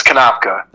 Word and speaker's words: Kanapka: It's Kanapka. – Okay. So, it's more like it's like a Polish background.